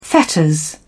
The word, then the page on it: fetters: British English